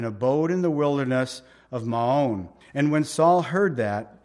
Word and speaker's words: Maon: But David and his men were in the wilderness of Maon. That's how you say it, in the plain of, on the south of, Now, this is literally Yishimon, is how you say it in the Hebrew, Yishimon.